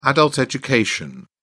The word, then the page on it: adult education: British English